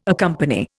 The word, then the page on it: accompany: Indian